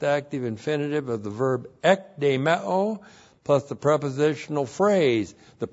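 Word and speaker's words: Ek de meo (ἐκδημέω): Ek de meo is the same verb we had in verse 6. Looks like that in the Greek, E-K-D, long E-M-E, long O. Ek de meo.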